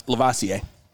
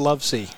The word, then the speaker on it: Lavoisier: Is that how you say it? – Lavoisier. – Okay, not Love C. – No, not Love C. – Okay. – No, it's like Cavassier.